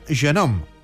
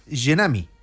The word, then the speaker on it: Jeunehomme: Jeunehomme, en Salzburgo, bueno, pues fue pronunciado de aquella manera y la gente pensaba que era Jeunehomme, en francés, joven, pero no tiene que ver.